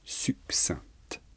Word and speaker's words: succinct: Et la difficulté, c'est qu'on ne prononce pas le C de succinct.